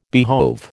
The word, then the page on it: behove: American English